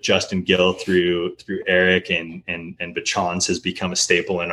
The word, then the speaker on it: Bachans: You said it correctly, by the way, in case anybody was wondering. – No, you didn't. It's Bachans. – Yeah, it's like Bachans.